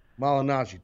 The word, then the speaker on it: Malignaggi: If I would have shot an angle with Paulie, you know, I mean, Paulie Malieri. How do you say? I always freaking up his name. – Malignaggi.